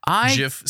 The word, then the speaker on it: Jif: Jif said with a soft G is America's number one peanut butter.